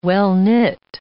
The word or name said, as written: well-knit